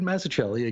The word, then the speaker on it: Mazzucchelli: It's like Massachusetts. Now, David Mazzucchelli,